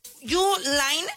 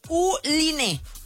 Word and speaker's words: Uline: Mira, no sé si estoy pronunciando bien, lo voy a pronunciar en español. Uline, Uline, ¿ok? U-L-I-N-E, Uline, o Uline.